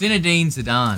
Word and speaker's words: Zinedine Zidane: And I knew how do you pronounce his name? – It's Zinedine Zidane.